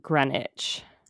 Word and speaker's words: Greenwich: So people pronounce Greenwich, Connecticut as Greenwich.